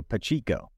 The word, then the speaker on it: Pacheco: Really, Isaiah Pacheco. That's what I've been told in the comment section, and that's what I've been messaged at that I've been consistently saying it wrong. So Pachico. I have like cheeks.